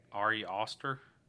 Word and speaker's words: Ari Aster: But yeah, version, so Ari Aster? Is that how you pronounce the name? Or is it Ari Aster? – Ari Aster? – Yeah.